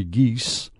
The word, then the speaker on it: Gies: The gift comes from Chicago businessman Larry Gies. His name is spelled G-I-E-S, Gies, and his wife, Beth.